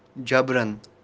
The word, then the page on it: jabran: kashmiri